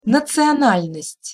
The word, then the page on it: nacional'nost': Russian